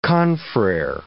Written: confrere

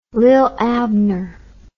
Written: lilabner

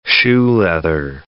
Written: shoe-leather

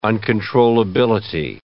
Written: uncontrollability

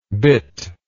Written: bitless

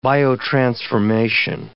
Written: biotransformation

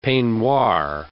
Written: peignoir

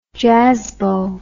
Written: jazzbo

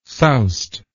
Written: soused

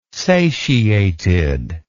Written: satiated

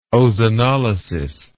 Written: ozonolysis

